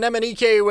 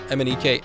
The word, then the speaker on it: MNEK: For the longest time when I was a bit younger, I used to call MNEK MNEK because it's spelled M-N-E-K. But then now that I'm spelling it, it just sounds so stupid to call it MNEK.